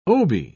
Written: obi

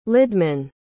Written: lidmann

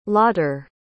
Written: lotter

